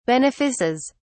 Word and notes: benefices: British English

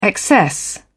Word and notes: excess: British English

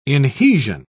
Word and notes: inhesion: British English

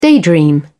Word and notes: daydream: British English

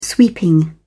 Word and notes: sweeping: British English

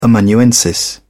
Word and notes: amanuensis: British English

